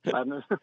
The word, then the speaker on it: Lebanon: I like how you pronounce Lebanon. – Lebanon.